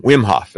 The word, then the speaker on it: Wim Hof: Is it Wim Hof? I think I'm saying it right. – Wim Hof. – That's it.